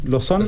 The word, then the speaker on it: Lausanne: Lausanne.